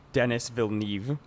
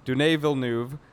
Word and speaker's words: Denis Villeneuve: and I pronounced it like Dennis Villeneuve, Right. Something like that. But Denis Villeneuve.